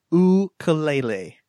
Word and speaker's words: ukulele: And the correct way to pronounce it is ukulele. Mm-hmm. Right? Is that right? – Yes. – I've been practicing. – I think it's ukulele. I think it's two oo sounds there in the middle, isn't it? – Ukulele.